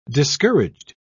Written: discouraged

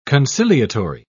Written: conciliatory

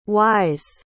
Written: y's

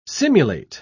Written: simulate

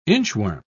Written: inchworm

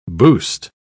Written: boost

